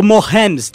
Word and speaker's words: Mahomes: É, o Patrick Mohanes, que o oh, Mahomes, desculpa, que Mahomes.